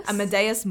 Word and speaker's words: Amadeus: And Wolfgang Amadeus, is that how you say his name? – Amadeus Mozart.